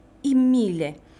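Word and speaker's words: I Mille: La historia los recordaría como Imile. Los mil. ¿Lo pronuncié bien, Alejandro? – Sí, perfecto.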